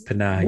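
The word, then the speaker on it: Panahi: We've been alternating a lot, but it's Pahani.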